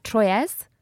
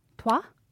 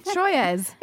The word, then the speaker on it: Troyes: So the last game where they clinched the title was against, and I'm going to check my French pronunciation here, Troyes. – Trois? – Troyes. – It's not a number. It's not. I'm not asking for three. It's T-R-O-Y-E-S, right, yes. The team, Troyes.